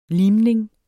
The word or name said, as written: limning